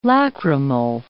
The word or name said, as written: lacrimal